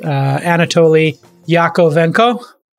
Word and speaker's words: Anatoly Yakovenko: Anatoly Yakovenko.